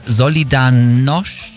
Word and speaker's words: Solidarność: Der das russische Regime mit seiner Solidarność... Ja, so würde man es aussprechen, ja.